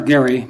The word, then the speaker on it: Garre: I am Albert Gary. My last name is spelled G-A-R-R-E.